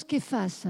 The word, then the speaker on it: Kephas: Alors, je prononce Kephas, hein. On l'écrit comme ça, mais le nom araméen c'est Kephas, c'est le nom araméen de Pierre,